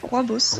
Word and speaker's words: rooibos: Le prochain, je sais pas comment le prononcer. Donc c'est rooibos. – C'est ça, rooibos.